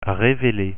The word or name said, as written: reveler